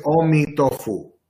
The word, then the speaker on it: Amitabha: His name is Amitabha. Amitabha. That's how they pronounce it.